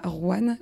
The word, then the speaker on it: Rouen: Donc tu n'es pas nantaise puisque tu as grandi à Rouen, j'espère que je le prononce bien. – Tu le prononces bien et même à côté de Rouen dans un petit village qui s'appelle Sevelinges qui est à une demi-heure de Rouen.